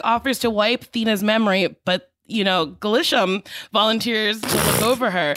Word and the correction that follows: Gilgamesh: Gilgamesh. Glisham. Gilgamesh. – Gilgamesh volunteers to, honestly, it's spelt the way it's pronounced.